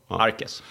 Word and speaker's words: Arquez: Q-U, precis som Nordqvist, Söderqvist. Uttalas K. Arquez.